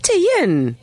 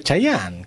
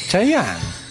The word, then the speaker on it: Chayanne: No, es Cheyenne. – Cheyenne, ¿cómo lo pronuncias? Chayanne. ¿Cómo Cheyenne? Chayanne.